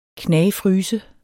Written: knagfryse

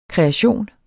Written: KREATIONEN

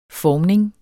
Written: formning

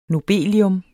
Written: nobelium

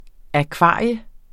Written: akvarium

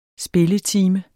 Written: spilletime

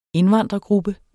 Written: indvandrergruppe

